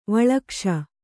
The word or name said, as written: ವಳಕ್ಷ